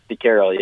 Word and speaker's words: De Keyrel: Yeah, DeCarroll.